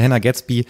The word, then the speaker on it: Hannah Gadsby: Ich hätte es gerne geschafft bis zu Hannah Gatsby, weil Hannah Gatsby man muss es einfach so aussprechen, Hannah Gatsby ist die Frau, die uns beide zusammengebracht hat.